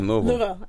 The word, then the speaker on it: Louisville: It's almost like you have to say Louisville and then put a bunch of marbles in your mouth, like Louisville. And then you say it, Louisville. – Louisville.